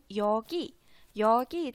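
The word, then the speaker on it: yogi yo (여기요): 其实本身 yogi yogi 这个词呢，它就是表示这里的意思啊，yogi 这里。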